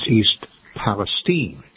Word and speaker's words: East Palestine: It's not East Palestine, Ohio. It's East Palestine, Ohio.